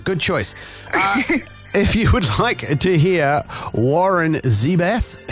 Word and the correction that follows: Zevon: how do you say his name? – Zevon.